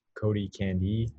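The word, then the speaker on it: Cody Candee: And then for me personally, all of my handles are at Cody Candy, and my last name is spelled C-A-N-D-E-E.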